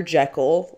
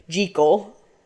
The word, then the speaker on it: Jekyll: The proper way to say this Scottish last name is really Jekyll. – Jekyll? – Yeah, not Jekyll, but Jekyll.